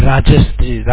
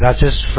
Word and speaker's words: Rajesh: Yeah, Rajesh. Rajesh from Dr. Gupta office.